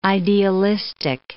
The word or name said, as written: idealistic